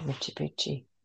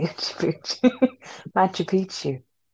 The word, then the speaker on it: Machu Picchu: Then yeah, I think I'd spend the rest of it on travel and go to like Machu Picchu.